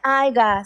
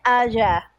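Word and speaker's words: haya: sabía que, pero me encantaron los TikToks de, Nene, no se dice aiga, se dice aya.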